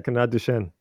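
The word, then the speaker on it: Canard Duchêne: I'm going to pronounce this really badly, the Canard Duchesne. – That's pretty much it. Yeah, Canard Duchesne. Yeah, so it has a little hat over the E, which means like eh.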